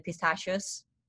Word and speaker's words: pistachio: They are supposed to be pronounced pistachio, for example, like, yes, with a K sound. It's written with ending C-C-H-I-O, but people pronounce it with sho when it's supposed to be kyo.